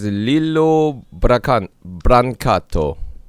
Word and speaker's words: Lillo Brancato: ich habe gerade gegoogelt, der heißt Lillo Brancato Junior.